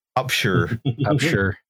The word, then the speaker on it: Upshur: Upshur. – Oh, hey, Halifax. – It's not Shire, it's Shur.